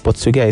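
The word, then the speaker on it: PortSwigger: Portswiga is spelled PortSwiger.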